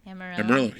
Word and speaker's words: Amarillo: Amarillo, yeah.